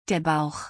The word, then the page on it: der Bauch: German